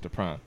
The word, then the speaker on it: Dupran: That's how it's pronounced, Dupran. – Just think of Dupran with a P.